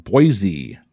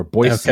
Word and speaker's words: Boise: Generally speaking, which U.S. capital city is pronounced with a Z sound by outsiders, but an S sound by locals? – Sacramento? – Unfortunately, not Boise or Boise.